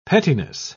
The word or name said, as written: pettiness